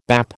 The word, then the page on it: bap: American English